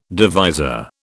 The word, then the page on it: divisor: British English